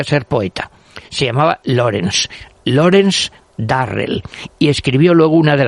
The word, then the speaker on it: Durrell: escrito con D de Dinamarca, los Durrell, pronunciado Darrell, que